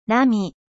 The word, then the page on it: vague: French